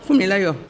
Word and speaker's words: Funmilayo: entoure, comment on prononce, Funmilayo ?»« Funmilayo.